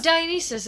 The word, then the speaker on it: Dionysus: Is it Dionysus? – No, Dionysus is, that's right.